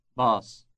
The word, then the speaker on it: باص: Bus. Bus. – Obviously, it is a borrowing, but with an elongated vowel.